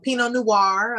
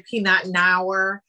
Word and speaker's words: Pinot Noir: Um, Pinot Noir. A lot of people say Peanut Noir.